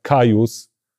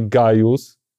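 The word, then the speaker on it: Gaius: I na przykład wiemy to, że e, k, g i c to, to był jeden dźwięk, czyli był na przykład kajus, nie gajus, tylko kajus, Julius. Tak? tak, Kajsar. Tak się to wymawiało.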